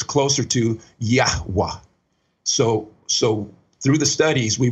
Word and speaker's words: Yahweh: And so we see the Yahweh, and it almost gives us three syllables, but we can also say it kind of fast down to two.